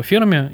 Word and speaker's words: Ферми: Кстати, я долгое время говорил, что парадокс Ферми, а правильнее все-таки, наверное, говорить парадокс Ферми, потому что Энрико Ферми, именно так ударение в его имени ставится.